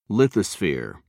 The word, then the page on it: lithosphere: British English